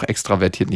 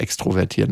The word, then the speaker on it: extravertiert: By the way, es heißt auch extravertiert, nicht extrovertiert. Ne? Hast du richtig gesagt. Extrovertiert ist eher umgangssprachlich.